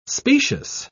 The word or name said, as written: specious